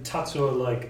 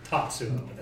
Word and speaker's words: Tatsuo: Tatsuo is T-A-T-S-U-O. And Kamishiga is K-A-M-I-S-H-I-G-A. – Kamishiga. – Okay. And then Tatsuo like Tetsuo from Akira, but Tatsuo.